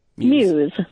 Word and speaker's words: Muse: Is it Muse or Muse? – Muse. – Muse. Okay.